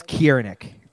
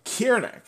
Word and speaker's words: Cirnik: I assume it's Cirnik. – Nope, I got you then. – Is it Cirnik? – Alex Cirnik. – Cirnik, it's a K sound, yes.